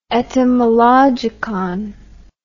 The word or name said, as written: etymologicon